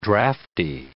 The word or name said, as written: drafty